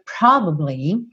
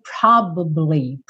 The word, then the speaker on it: probably: Essa palavra probably, muitas vezes a segunda sílaba... Muitas vezes a segunda sílaba dela some. Então, você não escuta probably. Fica probably. E aqui fica bem assim mesmo, você ouve.